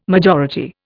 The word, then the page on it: majority: Indian